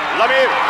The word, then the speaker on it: Lemieux: Please join me in welcoming number 66, Mario Lemieux.